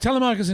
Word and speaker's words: Telemachus: Is that how you say it, Telemachus? – That's right. – Okay. Yep. It was Telemachus and George, and George died.